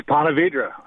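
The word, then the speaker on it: Ponte Vedra: Let's go to, is that Ponte Verde, Florida? Or is it, did I mispronounce it? – It's Ponte Verde.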